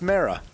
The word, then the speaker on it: Mara: He really was upset from earlier because I said it's pronounced Mara.